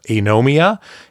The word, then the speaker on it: Anomia: But the game we are now playing that we borrowed from this other family is called Anomia. It's spelled A-N-O-M-I-A.